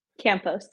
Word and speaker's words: Campos: Campos, yeah.